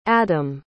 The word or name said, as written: adom